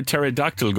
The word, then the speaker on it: pterodactyl: Why can't you hear a pterodactyl go into the bathroom? Because the P is silent. Pterodactyl is spelt with a P.